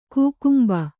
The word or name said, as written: ಕಕ್ಕಂಬು